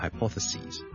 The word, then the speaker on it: hypotheses: So, hypothesis, hypothesis, plural, 我们是 SIS 转成 SES, 就变成 hypotheses, hypotheses, hypotheses, 记住个发音 ,ok? 由 sis 转成 sees, 你当好像是 CSEA 或 SEE, 那个 C, 跟住再加一个 S。